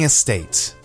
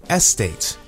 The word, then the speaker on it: estate: Housing estate. Many people say estate, but the correct pronunciation is estate with the emphasis on the second syllable.